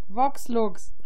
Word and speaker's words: Vox Lux: Wie ist der Film, den ich nicht aussprechen kann? Sonnenlux? – Vox Lux.